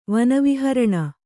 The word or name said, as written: ವನವಿಹರಣ